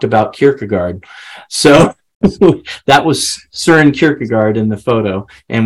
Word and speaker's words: Søren: And then Soren, or Søren, if you pronounce it properly, as the Danes would. But we're in the United States, so we say Soren.